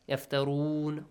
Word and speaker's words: يَفْتَرُونَ: You can extend the sound at the end when you stop. So, when the word ends with mad letter, yes? يَفْتَرُونَ.